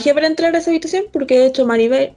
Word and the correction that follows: Mirabel: Maribel, Maribel, Maribel, ¿está bien? – Mirabel. Eh, Mirabel, lo dije mal.